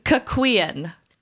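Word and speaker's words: cuckquean: Cuckween. I believe is how it's pronounced.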